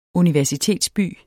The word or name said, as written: universitetsby